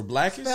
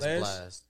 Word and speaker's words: Blxst: Is it Blast or Blackest? – Spell his name. – BLXST. – It's Blast. – Blast.